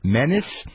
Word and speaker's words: Menes: You have, I believe it's pronounced Menes, M-E-N-E-S.